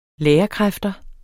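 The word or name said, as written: lærerkræfter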